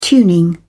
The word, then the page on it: tuning: British English